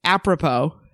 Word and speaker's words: apropos: But it was just like, I never, it's apropos, because it's spelled apropos. It's spelled weird for how you say it.